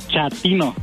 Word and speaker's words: chatino: Chapino. – ¿Chapino? – No, chatino. – Es como chato, ¿verdad? Chatino. – Sí, azunero, como chato, chatino.